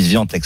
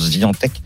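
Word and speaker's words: Świątek: Zviatek euh, d'ailleurs on dit Zviantek, ça s'écrit Zvia mais ça se prononce Zviantek La polonaise, elle a 20 ans.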